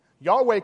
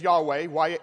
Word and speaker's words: Yahweh: The modern spelling as Yahweh, Y-A-H-W-E-H, includes vowels to assist in pronunciation. And then you often pronounce it more in our English as Jehovah. Or, of course, in Latin, you got to make sure you use an I. And we no longer know for certain what the exact pronunciation is, but we call him Jehovah.